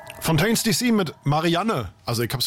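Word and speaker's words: Fontaines D.C.: Und du hast es wirklich falsch ausgesprochen, weil es ist Fontaines DC. – Fontaines DC.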